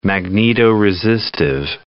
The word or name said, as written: magnetoresistive